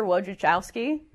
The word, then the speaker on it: Wojciechowski: Asher Wojciechowski, yeah, I said that right.